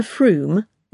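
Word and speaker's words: Frome: In her final year at Cambridge, nest of an earlier generation of spies, Serena Froome, rhymes with plume,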